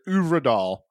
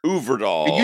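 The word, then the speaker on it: Øvredal: Let's get into the review for The Autopsy of Jane Doe, directed by Andre Orvidal. I probably fucked that up. – I told you before we recorded, Uvredal. – Uvredal.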